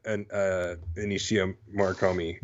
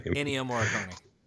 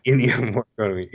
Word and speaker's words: Ennio Morricone: Take lyrics out, and this could have been on like the tribute to Ennio Morricone. I just butchered his name. – Ennio Morricone. Yeah. – Ennio Morricone.